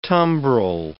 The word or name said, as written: tumbrel